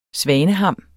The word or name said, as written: svaneham